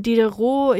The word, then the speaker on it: Diderot: Diderot, ich glaube, so wird er ausgesprochen.